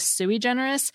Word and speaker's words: sui generis: The first is sui generis. And as an aside, I've always pronounced this sui generis, and that's listed as a second pronunciation in two American dictionaries I checked.